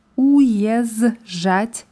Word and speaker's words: уезжать: Die ersten zwei Buchstaben sind u je. Уезжать wird das geschrieben und уезжать ausgesprochen.